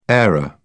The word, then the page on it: aero: British English